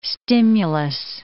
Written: stimulus

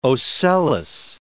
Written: ocellus

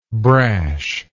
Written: brashness

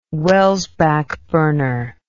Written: Welsbach burner

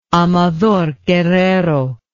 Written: Amador Guerrero

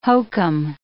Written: hokum

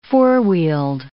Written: four-wheeled

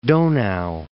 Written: Donau

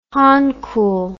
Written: Hankul

